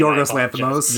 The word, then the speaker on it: Yorgos Lanthimos: Yorgos Lanthimos. – Yorgos Lanthimos.